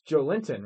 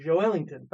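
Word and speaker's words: Joelinton: And everyone mispronounces his name. – It's Joe Linton, right? – It's Joe Ellington. – Oh, it is Joe Ellington.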